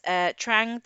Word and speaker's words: Trang: Right, so it's Trang, T-R-A-N-G. – Spell it for us, darling. – And then it's Thang, I think, T-H-A-N-H, and then Tran.